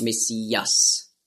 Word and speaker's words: Messias: Also darum wurde aus Masch, wurde Mess. Und dann Iach mit dem Het am Schluss, Mashiach, hat man früher ausgesprochen, Mashiach. Da hatten sie auch Mühe und darum haben sie daraus gemacht Messias.